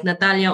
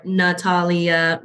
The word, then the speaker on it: Natalia: But I do a lot of times, like when I say my name, I'll say it how I say it, and then I'll say it without the accent. So I'll be like Natalia or Natalia Ronceria Ceballos.